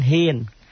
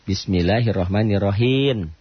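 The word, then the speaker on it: بسم الله الرحمن الرحيم: Jangan hin, him. Bibirnya nutup lagi. Bapak enggak sengaja kan? Tapi kedengaran orang, ya. Bapak bacanya bismillahirrahmanirrahim, bukan hin.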